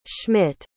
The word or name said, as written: Schmitt